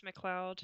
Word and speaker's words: McLeod: It's McLeod. – McLeod? – Like in Highlander. That's weird...